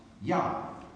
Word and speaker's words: Jah: There is no hard J sound, as far as I know, in the Hebrew language, so it's actually pronounced Yah.